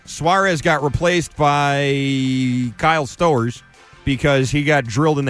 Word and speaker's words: Stowers: Okay, Stowers. I prefer Stowers because it rhymes with Knowers.